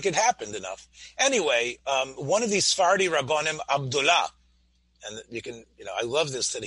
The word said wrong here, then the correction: Abdallah: The right pronunciation is Abdallah. – Abdallah.